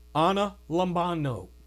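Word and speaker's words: analambano: It's a I'll spell it for you. A N A L A M as in Mary, B A N as in Nancy O. A N A L A M B A N O. Anna Lambano is what it's how it's pronounced in the Greek.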